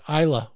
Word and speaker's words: Islay: If you're looking for one and you like the style that comes from the island, they call it Islay over there. It's spelled Islay.